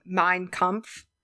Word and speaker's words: Mein Kampf: The book, I know that I'm going to say this wrong. Mein Kampf. – Yep. You got that one right.